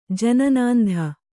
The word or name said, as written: ಜನನಾಂಧ